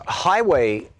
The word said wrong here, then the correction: Huawei: or how do you pronounce it, H-U-E-W-E-I, highway? – Huawei.